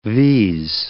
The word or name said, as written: vs